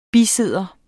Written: bisidder

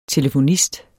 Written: telefonist

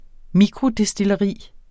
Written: mikrodestilleri

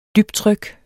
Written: dybtryk

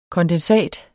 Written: kondensat

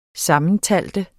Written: SAMMENTALT

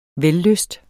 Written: vellyst

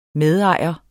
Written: medejer

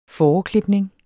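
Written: FÅREKLIPNING

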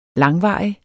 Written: langvarig

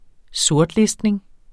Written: sortlistning

